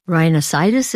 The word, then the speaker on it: rhinosinusitis: You have rhinosinusitis. Is that how you say it? – Yeah.